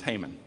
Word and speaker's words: Haman: Now, in Hebrew, it's pronounced Haman. In English, it's Haman.